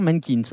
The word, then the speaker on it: MNQNS: Voilà, donc ça s'écrit M-N-Q-N-S, mais ça se dit, on est bien d'accord, Mankins, hein, c'est bien ça.